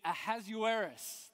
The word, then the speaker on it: Ahasuerus: It's Ahasuerus.